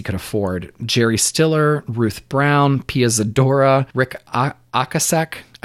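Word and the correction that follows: Ocasek: I looked up the pronunciation. Okasek? Okasek, damn it. Yeah. I looked up the pronunciation and still did it wrong.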